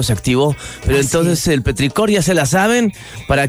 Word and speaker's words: petricor: La gota impacta en el suelo poroso y ahí sale el petricor, oye, ¿lo dije bien? – Petricor.